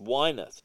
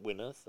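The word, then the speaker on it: Winath: And he's like, it's pronounced Wyneth, not Wyneth.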